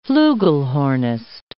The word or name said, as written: flugelhornist